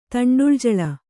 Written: ತಂಡುಳಜಳ

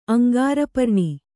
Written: ಅಂಗಾರಪರ್ಣಿ

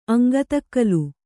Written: ಅಂಗತಕ್ಕಲು

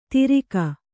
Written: ತಿರಿಕ

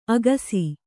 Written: ಅಗುಸೆ

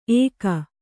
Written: ಏಕ

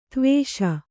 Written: ಧೀವಶ

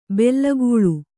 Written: ಬೇಲಿಕಳ್ಳಿ

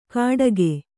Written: ಗಡಗೆ

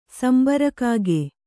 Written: ಸಂಬಾರಕಾಗೆ

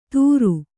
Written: ದುರು

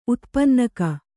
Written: ಉತ್ಪನ್ನಕ

